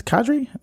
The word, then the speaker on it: cadre: Cadre? I don't even know how to pronounce that. – Yes. Cadre. – Crushed it. – Yes. Well done.